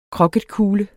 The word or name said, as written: kroketkugle